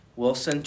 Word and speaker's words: Wilson: Wilson Chabal Figueroa. – And can you spell it for us, please? – Sure. First name Wilson, W-I-L-S-O-N. Last name Trabal, T-R-A-B-A-L hyphen Figueroa, F-I-G-U-E-R-O-A.